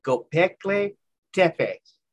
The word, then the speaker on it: Göbekli Tepe: It's Göbekli Tepe.